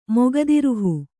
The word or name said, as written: ಮೊಗದಿರುಹು